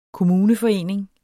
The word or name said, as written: kommuneforening